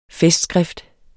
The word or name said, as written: festskrift